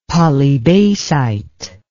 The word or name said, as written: polybasite